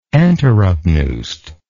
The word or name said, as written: enteropneust